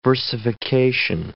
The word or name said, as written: versification